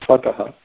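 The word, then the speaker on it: tvattaḥ: Tattva jnanam hijivanam, from you, tvata, from you, tvataha, I mispronounced that, tvataha.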